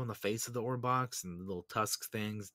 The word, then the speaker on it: Orbaks: These like horse type things, which are called Orbox. It's spelled O-R-B-A-K-S.